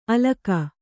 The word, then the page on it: ಅಲಕ: Kannada